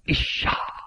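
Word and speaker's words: Isha (אשה): The Hebrew word Isha, I-S-H-A-H, pronounced like E-E-S-H-A-H.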